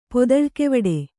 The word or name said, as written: ಪೊದಳ್ಕೆವಡೆ